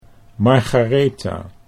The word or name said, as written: Margaretha